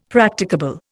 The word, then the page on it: Practicable: Indian